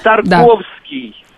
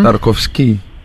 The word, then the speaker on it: Тарковский: Арсений Тарковский, да. – Советский поэт, переводчик, прозаик. Тут-то я ударение правильно поставил? – Правильно, да. – Тарковский.